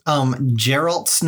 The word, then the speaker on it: Geralt: So one note, Geralt's name is pronounced Geralt.